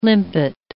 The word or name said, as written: limpet